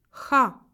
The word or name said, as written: kh